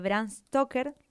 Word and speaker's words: Bram Stoker: que es un clásico de Bram Stoker. No sé si lo estoy pronunciando bien. – Tal cual, sí.